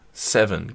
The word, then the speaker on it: Zevin: Die heißt Gabrielle Sevin. Sevin? Sevin? Sevin? Z-E-V-I-N? E Wie würdest du das aussprechen? Das ist Amerikaner. Z-E-V-I-N. E Seven. – Seven. Gabrielle Seven. Das ist Amerikanerin.